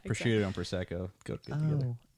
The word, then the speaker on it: Prosecco: Prosecco.